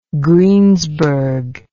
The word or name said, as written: Greensburg